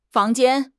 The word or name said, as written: fang2 jian1